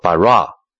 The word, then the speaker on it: bara: 创造的希伯来文读作 bara，虽然在旧约里 bara 这个字的含义非常的丰富，可是这个动词的主词总是神自己，这样呢就凸显了这个字的权威性。